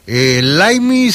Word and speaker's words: Limey: Laimi se escribe L y este, ¿cómo se llama? Espérame, L I M E Y, así se llama. – Laimi, ajá.